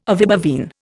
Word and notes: ovibovine: Indian

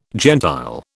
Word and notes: gentile: British English

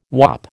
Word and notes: wop: American English

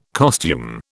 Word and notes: costume: British English

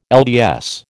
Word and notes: LDS: American English

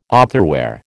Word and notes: otherwhere: American English

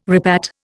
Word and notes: repat: Indian

